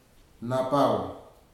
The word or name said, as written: napau